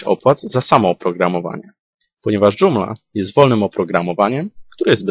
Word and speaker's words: Joomla: W języku polskim wymawiamy je Joomla.